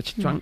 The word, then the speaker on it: Sichuan: En Sichuan, se pronuncia Sichuan, en China, hay una granja farmacéutica que produce 6.000 millones de cucarachas adultas al año.